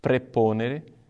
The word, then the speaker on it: praeponere: E a terceira palavra, preponere, é? o ae em latim pronuncia-se simplesmente e.